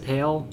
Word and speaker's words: Hale: Is that how you pronounce it, Hale? – Yeah, yeah.